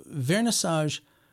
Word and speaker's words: Vernissage: One place that I went to, and it was the last day of my 2021 visit, was the Vernissage.